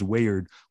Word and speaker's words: Weird: It's spelled like weird, but it's pronounced Wayard, which is actually an old English word that means fate.